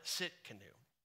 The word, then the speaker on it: Tsidkenu: And this is the covenant name right here is Jehovah Tsidkenu. Jehovah, the T is silent, Tsidkenu.